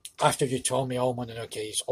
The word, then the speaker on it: Mononoke: So the next one will be Princess Mononoke. Mononoke. How do you would pronounce this? Well, I know English, they don't pronounce the E at the end. So it will be Princess Mononoke.